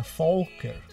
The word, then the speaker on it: Falker: O termo Falker, Falker, presta atenção na pronúncia, Falker, vem de folk.